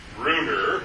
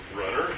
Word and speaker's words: Brunner: the glands of Brunner, or Brunner, however you want to pronounce it, Brunner's glands, which help you identify the duodenum and distinguish it from the jejunum ileum.